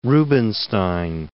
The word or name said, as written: Rubinstein